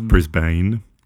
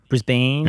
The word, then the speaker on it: Brisbane: I believe it's pronounced Brisbane. – Brisbane.